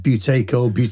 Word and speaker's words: Buteyko: Buteyko. Okay. Sounds a bit like potato, but yeah. yeah, buteyko is right, yeah.